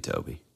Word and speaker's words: Tobe: It's spelled T-O-B-E. Do you think it's Toby or Tobe? I mean, it has to be Toby, right? – It's got to be Toby.